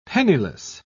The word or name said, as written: penniless